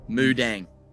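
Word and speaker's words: Moo Deng: Moo like a cow. D-E-N-G. Mudang.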